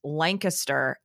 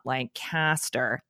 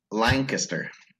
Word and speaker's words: Lancaster: And also, we should make sure everyone knows it is pronounced Lancaster and not Lancaster. That's very right. – important. – Lancaster.